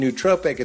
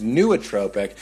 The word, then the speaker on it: nootropic: Someone just emailed me and said it's not pronounced nootropic, it's nootropic. I'm going to continue to say nootropic.